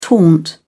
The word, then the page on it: taunt: British English